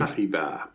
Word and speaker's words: Paribas: Is now owned by PSA and BNP Paribas, I think is how you pronounce it. – Paribas.